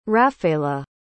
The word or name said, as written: raphaela